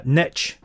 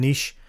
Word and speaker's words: niche: In fact, as it's pronounced in America, niche rather than niche.